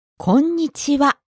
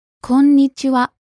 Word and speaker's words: Konnichiwa: Konnichiwa. – Konnichiwa. You hold the N sound for a little bit, don't you? – That's right. Konnichiwa. – Listeners, now you try it. Repeat. – Konnichiwa.